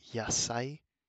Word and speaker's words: yasai: And next to the rice is a plate filled with veggies, or yasai. To spell that, it is YASAI.